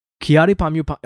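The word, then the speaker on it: Kyary Pamyu Pamyu: Have I been saying her name right? Kiari Pamyu Pamyu. Okay, good. Okay, I had a worry that I fucked that up.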